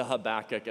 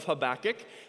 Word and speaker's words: Habakkuk: Let's talk about the pronunciation of Habakkuk. You could say Habakkuk or Habakkuk, or if you want to get really into the Hebrew, it involves a lot more phlegm and spit, and I'm not going to do that for you. But I'm going to go with Habakkuk because that's the pronunciation that I'm most comfortable with,